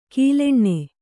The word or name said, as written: ಕೀಲೆಣ್ಣೆ